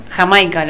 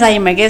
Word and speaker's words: Jamaica: Porque Led Zeppelin son ingleses. – Sí. – Y ellos, ¿viste cómo pronuncian? – Sí. – Ellos en realidad se dice Jamaica. Y en inglés estadounidense se dice Jamaica. ¿Entendés lo que te digo? – Sí. – O sea, no es Daymaker, es Jamaica.